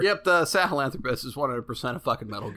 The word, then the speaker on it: Sahelanthropus: Seth tells you about the weapon that you need to kill the Lord of Dust, the Sahelanthropus. – Yep. – Sahelanthropus.